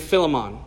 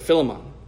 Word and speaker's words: Philemon: Paul is writing to a person named Philemon, or Philemon. There's a couple ways you can pronounce it. I've always said Philemon.